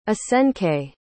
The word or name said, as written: asenke